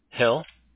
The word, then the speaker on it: Hill: Hill, H-I-L-L.